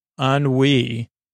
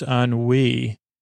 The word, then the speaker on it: ennui: Ennui is spelled E-N-N-U-I, the French ennui.